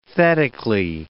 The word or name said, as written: thetically